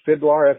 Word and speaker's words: Fidlar: It's pronounced that way, Fidlar, F-I-D-L-A-R. It stands for F It Dude, Life's a Risk.